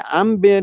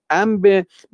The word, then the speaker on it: انبه: بلازوری میوه است که حالا مثل انبه که حالا در فارسی، در عربی به عین تلفظ میشه انبه، در صورتی که در فارسی به همزه تلفظ میکنن انبه.